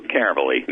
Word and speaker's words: Caverly: But you can send money orders, checks made out to WWCR or Global Star Communications, or cash to me, Lou Caverly, and that's L-O-U, and the last name is spelled C-A-V-E-R, L-Y, at Post Office Box 28, Bethlehem, just like in the Bible, Bethlehem, Maryland, 21609.